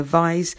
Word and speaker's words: Vise: We've got It Rhymes With Eyes, that's Julia Vise,